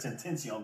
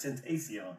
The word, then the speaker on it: XXXTentacion: It says Tentacion, but it's Tentacion.